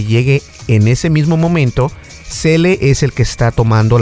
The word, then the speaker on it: Zelle: Se llama Zelle y se escribe Z-E-L-L-E, Zelle.